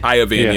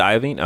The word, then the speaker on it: Iovine: Dr. Dre and Jimmy Iovine featured. – Iovine, yeah. – Is it Iovine? Okay.